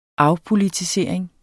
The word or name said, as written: afpolitisering